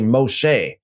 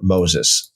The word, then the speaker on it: Moshe: By the way, Moses, the word or the name Moses is a really strange transliteration of the Hebrew, because the Hebrew is actually Moshe. And I think what happened here is just the Greek to make it compatible, or I want to say to align with the Greek New Testament kind of pronunciation, they just added an S on the end of here. So literally it would be Moshe, but we have Moses and Miriam.